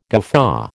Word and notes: guffaw: American English

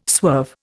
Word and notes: swerve: Indian

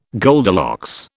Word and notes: Goldilocks: British English

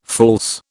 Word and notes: false: British English

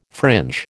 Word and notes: fringe: American English

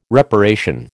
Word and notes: reparation: American English

